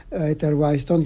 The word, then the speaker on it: Ethel Whitehorne: Un jour, il devait dîner avec une Anglaise qui était Ethel Whitehorne, que je prononce bien, Ethel Whitehorne,